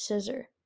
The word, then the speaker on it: Scizor: I call Scizor Scizor, and Pokemon calls it Scissor.